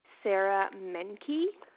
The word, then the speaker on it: Sarah Menke: Sarah Menke. Sarah with an H. And my last name is spelled M-E-N-K-E.